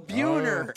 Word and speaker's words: Buhner: Bronner's. – No, Buhner. – Close.